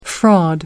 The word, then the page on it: fraud: American English